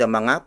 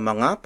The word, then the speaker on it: mga: Inherent noun roots can also become plural in form by adding the mga particle before the word. Mga is spelled as M-G-A.